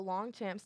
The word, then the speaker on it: Longchamp: And then this one stood out to me because when we were in New York, we went to the Longchamp store. Am I pronouncing that right? – Mm-hmm.